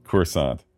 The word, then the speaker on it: Coruscant: Coruscant, which is what I wanted to say, but I'm so gun-shy.